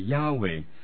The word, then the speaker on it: Yahweh: 所以上帝，我要解释这个牙威这个字，因为我是想将那个字数呢是控制在 4个字4个字，所以上帝的名字旧约是称之为耶和华，但其实另一个翻译就是更加贴切当时的原文 Yahweh 呢发音发出来就是 Yahweh，所以用牙威这个字，记得等姊妹的这个就是等于耶和华那个名字是相同的。